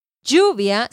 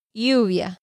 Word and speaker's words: lluvia: Entonces en España, rain is not lluvia, sino lluvia. It's more like you, you, como si fuera una I. So, rain en Latinoamérica, lluvia. En España, lluvia.